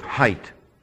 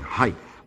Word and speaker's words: height: By the way, I don't want to suggest that you don't know how to pronounce words, but I hear more people saying height. There's no such word. It's height. No TH on the end.